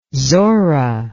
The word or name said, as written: zora